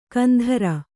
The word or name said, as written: ಕಂತ್ರಿ